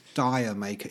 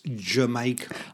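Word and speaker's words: D'yer Mak'er: And I had to tactfully explain it's not pronounced Dire Maker, it's Jamaica.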